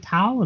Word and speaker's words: Tal: Do you go by, is it Tal? Am I saying that right? – Yes. – Okay. So that is correct.